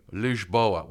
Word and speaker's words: Lisboa: Even we went to Lisboa, which I learned is the proper way to say Lisbon. So they say Lisboa. That's how they say it, which I didn't know.